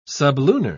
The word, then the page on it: sublunar: British English